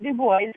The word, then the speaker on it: Du Bois: They pronounce it Du Bois because that's how W.E.B. Du Bois pronounced his name.